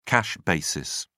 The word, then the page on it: cash basis: British English